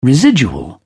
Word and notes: residual: British English

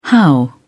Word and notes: how: British English

